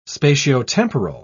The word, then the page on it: spatiotemporal: British English